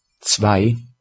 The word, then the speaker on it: Zwei: How to pronounce Zwei.